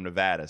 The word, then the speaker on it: Nevada: From everybody from Nevada, see, I pronounced it correctly.